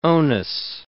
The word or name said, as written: onus